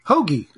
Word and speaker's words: Hoegee: How do you spell it? – Sure. – Hoagie. – It's Hoagie. – H-O-E-G-E-E. – Looks like Hoagie to me.